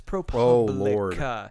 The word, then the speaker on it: ProPublica: It's like when people pronounce ProPublica and it drives me nuts. I'm like, it's ProPublica. – Oh, do not say Publica like it's a. It sounds like too many things that aren't news related.